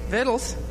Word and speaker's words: victuals: And they took all the goods of Sodom and Gomorrah and all their victuals and went their way. I thought victuals was a southern term. Vittles? Oh, victuals. Vittles. Is that how you pronounce it? Vittles? Oh, well, I'm from the north. Victuals.